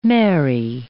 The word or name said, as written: Mary I